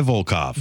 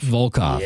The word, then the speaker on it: Volkov: Nikolai Volkov. – Volkov.